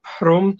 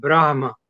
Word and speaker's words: Brahma: It was called the Prom Temple, which is a local pronunciation of Brahma.